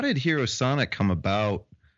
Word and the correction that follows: Hyro Sonic: Hyro Sonic, I'm sorry, I'm saying it wrong.